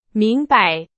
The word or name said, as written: 明白